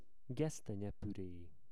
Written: gesztenyepure

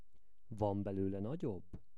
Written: van belole nagyobb

